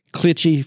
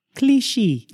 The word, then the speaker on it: Clichy: I'm going to say that's not the right pronunciation. – You mean my southern pronunciation of Clichy, France is not correct? – I'm sorry. It's sad to say, I'm pretty sure that's not it. – Okay, give me your French pronunciation. – Clichy.